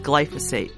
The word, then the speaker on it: glyphosate: The topic of today's show is glyphosate, a word that Greta taught me how to pronounce.